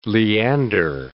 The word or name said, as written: Leander